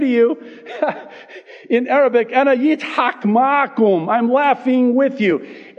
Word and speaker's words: Yitzhak: Now, we pronounce the name in English, Isaac. But in Hebrew, it's Yitzhak.